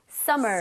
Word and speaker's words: summer: Summer. – Summer. Right. M이 두 개 있지만 이걸 굳이 이렇게 나눠서 발음하지는 않아요. Summer 이렇게 하지 않습니다.